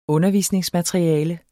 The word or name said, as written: undervisningsmateriale